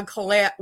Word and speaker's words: Cailleach: It's It's pronounced many different ways. It's a Celtic name. – Okay. – So actually, my name's Arla Cailleach Williams, okay.